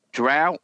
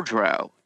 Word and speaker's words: drow: So do you know that it says drow or drow? You can do either.